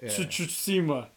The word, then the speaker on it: Tsushima: Tsushima.